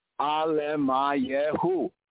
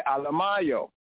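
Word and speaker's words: Alemayehu: All right, now if we just pronounce it as we did, slowly, Alemayahu, Alemayahu. Now when we say it at a more natural speaking pace, you know, we say Alemayo, Alemayo, Alemayo.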